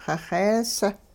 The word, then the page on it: xixees: hupa